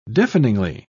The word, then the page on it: deafeningly: British English